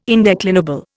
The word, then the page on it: indeclinable: Indian